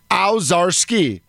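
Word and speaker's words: Owczarski: It's at Jim, ow, O-W. Then he's the czar, C-Z-A-R. And then you go skiing, Ozarski. It's Ozarski, but...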